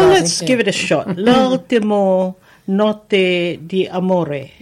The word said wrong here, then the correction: d'amore: D'amore. – Not too bad.